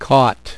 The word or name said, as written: cot